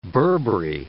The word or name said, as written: Burberry